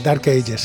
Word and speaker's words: Dark Ages: Que prefiero que lo pronuncies tú mejor. Dark Ages. – Dark Ages, sí.